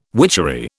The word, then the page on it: witchery: British English